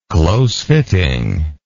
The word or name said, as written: close-fitting